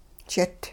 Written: jit

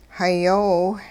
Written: hayoow

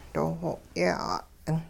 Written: doo xwo yeh 'at'ing

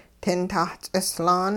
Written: tintah-ts'islaan

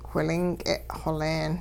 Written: whilink'e xoleen

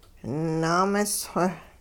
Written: naamis-xw

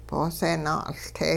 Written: boose naa'uLte

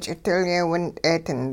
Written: ch'idilyee-wint'ee-ding

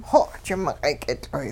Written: xoji miq'ayk'itL'oy'

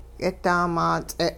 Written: yidaamaats'e'

